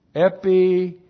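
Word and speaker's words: Epipotheo (ἐπιποθέω): Well, we start out with the verb epipotheo. Epipotheo looks like that in the Greek. E-P-I-P-O-T-H-E, long O. Epipotheo.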